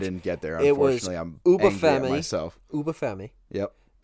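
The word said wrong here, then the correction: Oba Femi: It's Oba.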